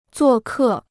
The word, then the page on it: 作客: zuò kè